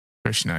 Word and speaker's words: Krishna: Boston Brand was selected by by Kushnu. Uh, I want to say, did I say that right? Kushnu? – Krishna, I think. Krishna.